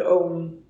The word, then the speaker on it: Ogham: So our medieval Ireland has um, a medieval tree alphabet from like around the 5th century called Om, like the meditative word that is the original sound of the universe, maybe. It's spelled O G H A M.